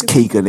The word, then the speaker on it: Keoghan: How do you pronounce his name? – I'm going back to bed. – Oh, what a disaster. Dear God. Can I join you? – Is it Not Keoghan? – It is Keoghan.